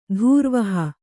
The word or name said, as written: ಧೂರ್ವಹ